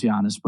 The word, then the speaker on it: Giannis: The one and the two seeds, Milwaukee and Toronto, assuming Kawhi and Giannis don't get, I always say Giannis. I'm never going to say Giannis. Sorry. So it's probably pronounced Giannis, but,